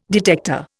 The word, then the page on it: detector: Indian